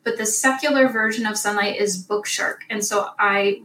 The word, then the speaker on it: Sonlight: Sunlight Science, that's a Christian version, and it's spelled S-O-N-L-I-G-H-T.